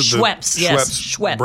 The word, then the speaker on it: Schweppes: The Brown family found the Schweppes, is that how you pronounce it? – Schweppes.